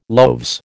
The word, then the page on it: loaves: American English